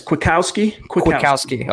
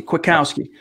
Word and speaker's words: Kwiatkowski: Yes, Kwiatkowski. – Okay. – Like saying quick and owski, Kwiatkowski.